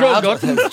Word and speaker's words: Schogetten: Schogetten. German pronunciation was very good there.